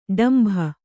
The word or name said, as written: ಡೊಂಬೆ